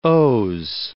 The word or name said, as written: O.